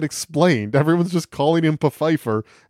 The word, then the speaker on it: Pfeiffer: It's the misadventures of Abraham Lincoln, his loony associates, and the only sane man among them, the president's black butler, Desmond Pfeiffer. – Yeah. – It is pronounced Pfeiffer. – Yeah. – It's spelled like Pfeiffer, like Michelle Pfeiffer.